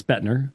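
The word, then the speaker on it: Boettner: So Augustine was kind of the first, I think it's Lorraine Botner or Bettner, however you pronounce that, who's also a Calvinistic historian who admits that Augustine is the first to introduce these things. – Lorraine Botner. Okay. Yeah, it's Bettner.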